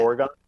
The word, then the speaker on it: Oregon: Yesterday, I posted a recap, and I pronounced Oregon Trail is what I said. – Yeah. – Oregon. I don't think I've ever gotten so much hate in my life. – What is it? Because I would have said Oregon, too. – The entire state of Oregon.